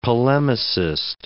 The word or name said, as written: polemicist